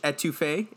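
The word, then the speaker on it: étouffée: Louisiana's, because we just recently went there, it was crawfish etouffee.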